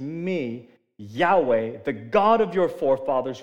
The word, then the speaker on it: Yahweh: The I am that's pronounced Yahweh and no one in Jewish culture says it because it's too holy to say, that I am?